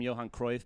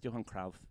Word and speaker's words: Johan Cruyff: But you know, like they keep calling him Johan Cruyff, but it's Johan Cruyff.